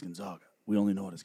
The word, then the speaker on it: Gonzaga: And everybody was calling them Gonzaga.